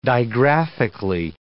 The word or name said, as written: digraphically